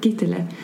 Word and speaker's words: Gitterle: Men ska vi gå in och bara nämna de här 9 åldrande mekanismerna som Dr. Gitterle, är det så man uttalar? – Gitterle.